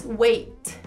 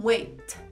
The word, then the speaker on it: wait: No inglês norte-americano, a gente vai interromper e soltar um arzinho quase que mudo, é, interrompido. E no inglês britânico, eles continuam pronunciando um T normal. Então, por exemplo, a palavra esperar, aguardar, no inglês britânico se diz wait continua fazendo esse som, wait.